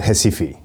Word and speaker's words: Recife: You were raised in a town called Recife.